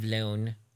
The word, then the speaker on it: Lone: And Benjamin Bratt's character, Detective Lone, rhymes with Sloan Bone.